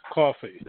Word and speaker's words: Cawfee: I'm from New Jersey, so I'll pronounce it the Jersey way, Cawfee.